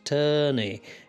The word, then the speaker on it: Tony: He was called Tony, which is pronounced Turney in Hull.